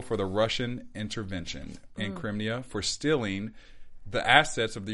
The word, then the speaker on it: Crimea: Crimea? – Sure. – Okay, we're going to go with Crimea.